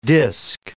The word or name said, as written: disk